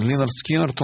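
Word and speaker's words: Lynyrd Skynyrd: Я хотел сказать, что Линард Скиннард у нас в фрагментах первого альбома Pronounced Leonard Skinnard.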